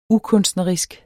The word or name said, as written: ukunstnerisk